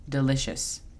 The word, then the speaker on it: delicious: La pronunciación fonética es P-L-E-I-N, plain. Second, segundo. Delicious, delicioso. La pronunciación fonética es D-E-L-I-S-H-A-S, delicious. Third, Tercero, energetic. Energetic significa enérgico. La pronunciación fonética es E-N-R-G-E-T-E-C.